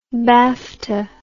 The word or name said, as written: bafta